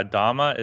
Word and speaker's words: Dhamma: Okay, yeah, Dhamma is the Pali pronunciation of it and the Pali spelling, and Dharma is the Sanskrit.